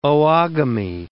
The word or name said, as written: oogamy